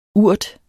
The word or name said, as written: urt